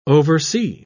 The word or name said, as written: oversea